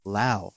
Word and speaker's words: Lowe: Brandon, I called him Brandon Lowe. He's actually Brandon Lau, right? Rhymes with now.